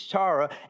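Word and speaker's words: chara (χαρά): So in the Greek, I immediately wanted to say chara, but that's C-H-A-R-A, and you pronounce that, so it's chara, apparently, right? Somebody? All right, cool.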